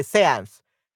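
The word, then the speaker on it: séance: Yeah, sciences, sense, seance. Okay, seance. That's the pronunciation.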